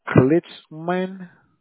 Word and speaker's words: Klitzman: Or maybe I mispronunciated his last name. Let me spell it for you. It's K as in Kilo, L as in Larry, I as in ice cream, T-Z-M-A-N. Klitzman. Chad Klitzman.